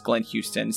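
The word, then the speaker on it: Glenn Huston: as well as my personal account is Glenn Houston, spelled like Huston, family name. – Yes, Houston without the first O. – Without the O, yeah.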